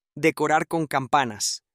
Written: decorar con campanas

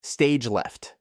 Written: stage left

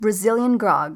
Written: brazilian grog

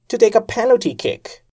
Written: to take a penalty kick

